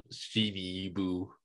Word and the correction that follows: Shiba Inu: Shiba Inu. – That's it. Shiba Inu.